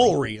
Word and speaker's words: Woolery: Did I not say it correctly? – Woolery is right.